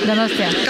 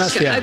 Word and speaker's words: Donostia: Donostia. – Gosh. – Donostia.